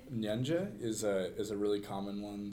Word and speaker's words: Nyanja: But just about everybody in Lusaka will know Nyanja. – Okay. It sounds like you're saying ninja. – Yeah. It's like N-Y-A-N-G-E. Nyanja.